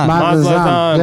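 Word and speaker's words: Mazatlán: Mazatlan. – Mazatlan.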